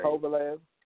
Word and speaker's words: Kovalev: How do you pronounce his name? – Kovalev.